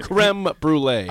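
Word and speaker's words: crème brûlée: They say creme. – Is it creme? – I'm just helping with the pronunciation. Yeah, creme brulee. – I had that yesterday. Is it creme? – I told you before the show, it's creme. Yeah. I know it looks like it's C-R-E-M-E, but it's creme brulee.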